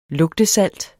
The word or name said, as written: lugtesalt